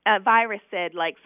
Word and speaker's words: Viracid: Viracid, it's spelled V-I-R-A-C-I-D.